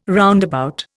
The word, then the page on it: roundabout: Indian